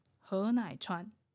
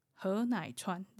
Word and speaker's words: 禾乃川: 現地の発音にすると、河内川、河内川ですね。